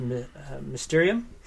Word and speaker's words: Mysterium: But another really good board game to pick up is Mysterium. Yeah, that's how you pronounce it.